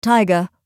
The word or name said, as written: taiga